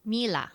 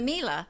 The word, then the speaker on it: Miele: Miele. – Miele. Miele. – Miele. Like Sheila. Miele. – Okay. So the Miele, would probably fit much better.